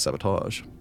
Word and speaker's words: sabotage: Yes, I know it's correctly pronounced sabotage, but I say sabotage.